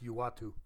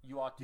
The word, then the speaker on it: Uatu: I don't even know how to pronounce it, but it's Uatu. – Uatu. – U-A-T-U, the Watcher. Good pick.